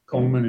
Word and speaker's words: Coleman: Richard, R-I-C-H-A-R-D. Coleman is spelled C-O-L-E-M-A-N.